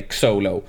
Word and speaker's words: Xolo: Men Sholo Mariduena. – om det nu är Sholo eller Xolo. – men det är X-O-L-O. – Ja. men jag tänker Sholo.